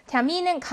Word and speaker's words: Termin: Termin은 강세가 여기 있어요. 그럼 강세 모음 뒤에 자음의 하나이니까 요 이는 길게 발음이 납니다.